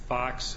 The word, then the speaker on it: Fuchs: And the main witness was a Jerry Fox, it's spelled F-U-C-H-S, who was the main investigator as a part of it.